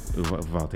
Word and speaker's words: Uvalde: And there has been a horrible shooting in Uvalde. I hope I'm pronouncing that correct. – Yeah, Uvalde, yeah. – Uvalde, yeah.